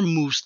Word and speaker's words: mousse: She's like, no, it's mouse cake. It's French. And I was like, that's not how the French pronounce mousse. If anything, they say it more mousse than we do.